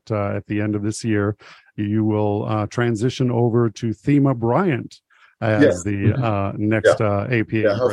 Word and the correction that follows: Thema: Yeah, she pronounces it Tema.